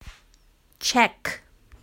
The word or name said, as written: check